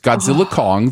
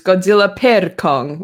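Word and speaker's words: Godzilla x Kong: The director has specifically said the X is silent. So, did he really? – Yes, it's Godzilla Kong, the new empire.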